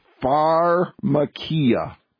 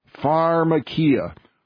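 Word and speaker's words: pharmakeia: Here it is, pharmakia. Pharmakia. What does that word sound like? Well, folks, it sounds like the word pharmacy, and you are exactly right.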